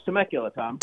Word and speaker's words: Temecula: Am I saying that right – No, it's Temecula